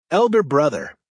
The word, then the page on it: elder brother: American English